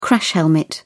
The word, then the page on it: crash helmet: British English